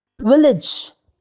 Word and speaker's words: village: D-I-A-R-Y. Diary. V-I-L-L-A-G-E. Village. It is not village. A-G-E should be pronounced as idge. Village. The last word, F-O-O-D.